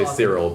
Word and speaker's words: Kirill: That's, I mean, in English we say Cyril, but in that time they would have said Kirill. – All right. – Hard C's. – Hard C's all across.